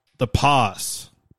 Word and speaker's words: The Pas: Le Pas is how they say it in French.